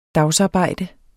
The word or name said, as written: dagsarbejde